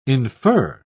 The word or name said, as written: infer